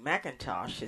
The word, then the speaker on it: McIntosh: My name, Cynthia, is spelled S-Y-N-T-H-I-A, and my last name, McIntosh, is spelled M-C-I-N-T-O-S-H.